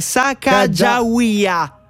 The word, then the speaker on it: Sacagawea: Sacacata? No. – Allora, è Sacagawea.